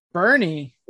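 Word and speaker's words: Boerne: Bernie. It's pronounced Bernie. – I could see that. – So, Bernie 2020. – Sanders. – Everybody, when yeah, right. Everybody, when they name the towns in Texas, they're like, hey, we're gonna pick one letter out of that entire word, and we're not going to pronounce it.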